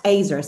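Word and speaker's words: Azer (עזר): It's pronounced E-Z-E-R. Thanks, Rebecca. E-Z-E-R, and it's pronounced Azer, so A-Z-E-R, or A-Z, Z in English, UK. A-Z-E-R, Azer.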